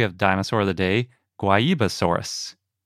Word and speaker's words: Guaibasaurus: It's a dinosaur from Brazil, so we're going with the Portuguese pronunciation. – Yeah, it's spelled G U A I, Basaurus, if you can't tell by how we're pronouncing it.